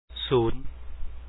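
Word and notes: ສູນ: su 2 un

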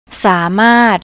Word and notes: samart: thai

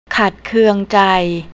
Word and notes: katkaung: thai

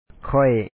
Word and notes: ເຈົ້າ: càw

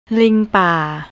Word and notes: lingpar: thai

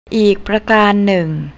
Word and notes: aeek: thai

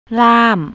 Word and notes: lam: thai

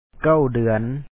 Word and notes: ສອງ: sɔ̌ɔŋ

